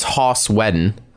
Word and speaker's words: Joss Whedon: Everyone pronounces his name wrong. It's Joss Whedon.